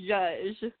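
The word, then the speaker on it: Jouzge: It's pronounced Judge.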